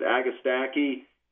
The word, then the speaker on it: agastache: One of your specialties, I've noticed, is that, is it pronounced agastache? – Yes, you can pronounce it agastache, agastache, agastache. – Okay. – A lot of ways to pronounce it, but if you come into any nursery that's familiar with native plants, we'll know what you're talking about.